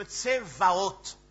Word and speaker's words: Tsevaot: Jézabel, de l'hébreu, Isabel ou Isabel, puisque le B et le V en hébreu, eh bien, vous avez la même graphie, c'est le bête, tantôt ça se prononce V, tantôt ça se prononce B. C'est ainsi que dans le chant qu'on a chanté, Elohim Tsevaot. Mais bon, on écrit Tsevaot parce que nous, V et B, chez eux, c'est la même lettre.